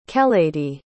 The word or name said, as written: keleidy